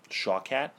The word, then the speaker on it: Shawkat: Shawkat. – Ally Shawkat.